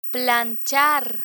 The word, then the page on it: planchar: Spanish